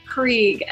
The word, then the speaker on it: Krieg: Krieg, as in the German war strategy.